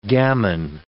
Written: gamin

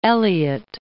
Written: Elyot

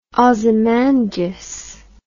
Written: Ozymandias

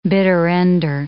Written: bitter-ender

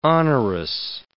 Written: onerous

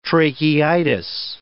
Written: tracheitis

